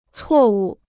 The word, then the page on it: 错误: cuò wù